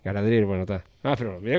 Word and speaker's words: Galadriel: No es Gabriel. No, Gabriel no, Gada, ¿cómo es Galadriel.